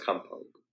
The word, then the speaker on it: compote: I haven't been feeling good, very good, and I kind of did a cop out for dinner, and I made it's pronounced compote. – Not cop out.